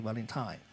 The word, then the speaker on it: My Bloody Valentine: 哎，这发音不太对啊，Valentine 啊，My Bloody Valentine。